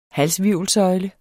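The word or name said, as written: HALSHVIRVELSØJLE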